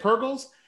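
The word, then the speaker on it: Purrgil: Yeah, the Purgils. – Is that how you say it? Purgils? – Yeah, there's an image circulating around that they found this blob in space, and it looks like a Purgil.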